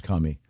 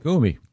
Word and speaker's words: Kumi: I believe it's pronounced Kami. – No, it's not. – Hey, Kami. – You mean Kami like a Russian? – K-U-M-I. – Oh. – Kumi.